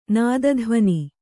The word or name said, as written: ನಾದಧ್ವನಿ